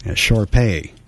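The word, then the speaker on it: Shar-Pei: All right, we're looking for Moxie Lost, a short-haired red Sharpie mix. Sharpay, excuse my pronunciation there.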